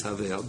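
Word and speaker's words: Havel (הבל): and the correct way to say it is Havel, but it does mean Veloshim Tzivu.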